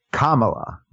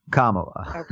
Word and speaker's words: Kamala: So you got to, it's Kamala. Kamala.